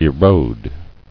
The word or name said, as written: Eroding